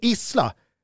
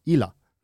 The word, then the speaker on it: Isla: Mauricio Isla, som man senare i livet lärde sig borde vara Illa. Men man byter inte uttal på Isla.